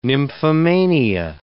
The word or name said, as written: nymphomania